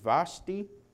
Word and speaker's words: Vashti: I believe you pronounce her name Vashti.